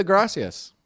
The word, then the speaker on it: gracias: Dude, start with a gracias. – Gracias. – That's actually good. You kind of roll your R a little bit.